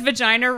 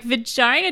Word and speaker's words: vagina: It was an English book, which is why when it said vagina rhymes with Regina, I was like, vagina doesn't rhyme with Regina.